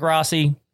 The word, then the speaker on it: Rossi: So today, guys, we've got a guest, Dominic Rossi.